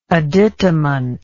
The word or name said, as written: additamentary